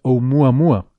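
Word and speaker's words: Oumuamua (ʻOumuamua): porque dijimos que entonces se pronuncia Oumuamua, pero he estado viendo estos días en conferencias por ahí que dan colegas en Estados Unidos que realmente la pronunciación correcta es Oumuamua que de hecho suena mucho más hawaiano.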